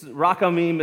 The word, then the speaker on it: rachamim: So it looks like that in Hebrew, and it's rachamim is how we're going to pronounce it this morning.